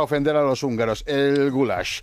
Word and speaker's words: goulash: El goulash es pronunciado así, goulash.